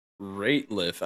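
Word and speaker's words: Rateliff: Nathaniel Radcliffe, dude or band with dude name? – So it's Nathaniel Ratliff. I don't know. I don't even know how to say it. – Ratliff, sorry.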